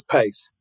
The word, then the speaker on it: Paice: Italians can say Paice, but it's Pace.